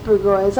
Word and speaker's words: Bourgeois: So it is pronounced Bergois. I always thought reading it that it looked like Bourgeois. – It is Bourgeois, but we call him Bergois. Mr. Dan Bergois.